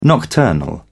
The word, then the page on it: nocturnal: British English